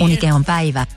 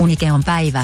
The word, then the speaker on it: Unikeonpäivä: Also ich kann es nicht so gut aussprechen, aber da gibt es ja dafür auch führende KI. – Unikeonpäivä. – Dafür kann man die KI gut gebrauchen. – Unikeonpäivä.